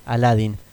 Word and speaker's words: Aladdin: Yo sé cómo se ponía, lo escribí mal, pero vos lo pronunciaste mal directamente. Aladdin.